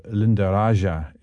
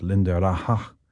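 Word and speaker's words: Lindaraja: A little tone poem by Debussy, and I didn't say it right before because it's inspired by Spain, so Linda Raja isn't right, it's Linda Raja, inspired by Alhambra, because Linda Raja refers to a garden courtyard in that part of Spain. Maybe Debussy visited, or maybe just it was a picture.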